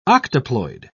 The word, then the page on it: octoploid: British English